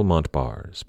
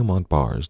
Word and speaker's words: Montbars: Now, before we move on, I'd like to point out that Daniel Montbars did not pronounce his name that way. He was French, after all. His last name was pronounced something a little closer to Montbar, but in the interest of not butchering the French language for the entire episode, I'm going to stick with the anglicized version.